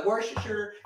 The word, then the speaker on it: Worcestershire: I mean, hey, Google, how do you pronounce Worcestershire? – That's pronounced Worcestershire.